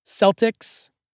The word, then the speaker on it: Celtic: Early pronunciations were with an S sound due to the word's French origin. But the modern standard is a hard C, like K, to give credence to the word's Greek and Latin origins. The decision makers of this stuff were as confused as Moltisanti trying to find a song to play in his final moments. Now, the soft C pronunciation is pretty much used only for the Boston Celtics.